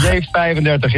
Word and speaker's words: J35: Maar het gaat, zeg ik het goed, het gaat om de Gibson J35. – J35 is het, ja.